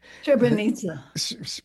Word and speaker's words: Srebrenica: One was the Srebrenica massacre. – Srebrenica. – Thank you. It's been so long, I've forgotten how to pronounce it.